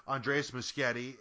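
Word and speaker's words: Andrés Muschietti: I mean, the new film, which was you know, directed by Andreas Muschietti, which I'm sure I said that incredibly wrong. – I think you nailed it. I think that's exactly how you pronounce it.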